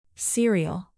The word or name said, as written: cereal